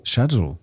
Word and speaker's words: schedule: Natomiast w Nowym Jorku to już będzie schedule. – Schedule. – Schedule przez K. Czyli jeśli ktoś woli American English, to bardzo zachęcamy, żeby to słowo wymawiać właśnie przez K i przez G, czyli schedule.